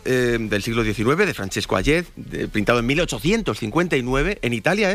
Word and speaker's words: Hayez: Vienes a descubrirnos el cuadro El beso de Hayez, se pronuncia, ¿no? – Hayez. – Podríamos decir. Francesco Hayez.